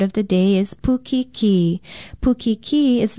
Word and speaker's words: pukiki: Pukiki is spelled P-U-K-I-K-I with a kahako or stress mark over the last vowel sound.